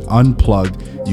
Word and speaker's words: UNPLGD: So if you're listening to this podcast for the very first time, it's spelled UNPLGD, Unplugged.